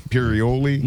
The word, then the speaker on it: Imperioli: Imperioli.